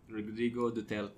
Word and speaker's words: Rodrigo Duterte: Rodrigo Duterte.